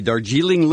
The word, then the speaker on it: Darjeeling: Talking about the Darjeeling Limited.